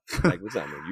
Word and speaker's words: Leguizamo: Leguizamo. Yeah. – Leguizamo.